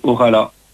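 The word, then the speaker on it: Ojalá: c'est « Orala ». Ça s'écrit « O-J-A-L-A » et c'est sur le projet. – Voilà, Orala,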